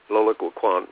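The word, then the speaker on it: Loïc Wacquant: Sociologist Locke Wacquant, how do you say his name? – Loic Wacquant.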